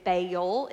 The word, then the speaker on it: Baal: In Texas, we say Baal. It's a two-syllable word.